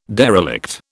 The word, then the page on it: derelict: British English